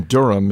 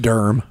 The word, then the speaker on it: Durham: And from Vicky Gardner in North Carolina, who writes, we have two-hour delays several times a year here in Durham and the rest of the Piedmont. – It's pronounced Durham.